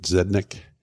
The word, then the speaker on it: Zednik: I think you say that right. Is it Zednik? – Yeah.